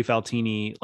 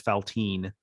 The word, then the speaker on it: Faltini: Yeah, I definitely mispronounced Trey Faltini like at least halfway into his first season as Faltine.